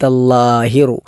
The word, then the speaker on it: الظاهر: ഒന്ന് വഅൻത അല്ലാഹിറു, അല്ലാ എന്നത് നാവ് മുൻപല്ലിൻ്റെ മുകളിൽ തട്ടുന്ന രൂപത്തിൽ ലാ ആണ് ഉച്ചരിക്കേണ്ടത്.